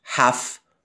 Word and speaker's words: half: Half نوشته میشه، half خونده میشه. تو تلفظ انگلیسیش میشه half. امریکایی ها میگن half.